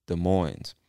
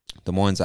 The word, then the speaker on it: Des Moines: And it's spelled, just in case you're not watching, D-E-S space M-O-I-N-E-S, Des Moines. Des Moines, Iowa. Actually, I think the S is silent. Des Moines, Iowa. But I'm not 100% sure about that, actually. Des Moines or Des Moines. I'm sure the S is silent because I believe it comes from French. And most of the time, those S's at the end, they're silent. Anyway, moving on to the next state, which is Kansas.